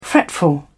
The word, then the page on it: fretful: British English